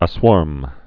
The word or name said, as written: aswarm